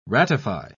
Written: ratify

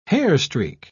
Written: hairstreak

